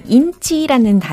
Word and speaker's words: inch: 인치가 아니고요. 인치. 인치. 이렇게 발음이 되는데 I give you one inch.